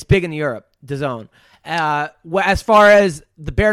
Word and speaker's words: DAZN: And then when I looked it up, it said that it's pronounced DAZN. And I went, fuck you. I'm not putting that. I hate you. – So that's the zone.